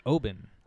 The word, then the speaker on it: Oban: Technically, it's pronounced Oban.